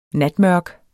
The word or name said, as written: natmørk